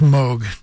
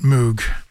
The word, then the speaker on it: Moog: By the way, it's pronounced Moog, not Moog.